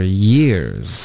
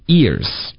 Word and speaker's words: years: I've done it for years. – I've done it for years. – Good. 这个 years 的发音，我觉得很多的学生在这边也会出问题，会把它发成 ears，耳朵。 – 对，这就是耳朵。